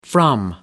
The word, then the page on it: from: American English